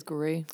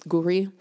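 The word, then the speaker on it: Gwri: It's spelled Guri. It's G-W-R-I. – I think it is Guri. – Guri.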